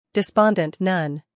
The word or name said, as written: despondent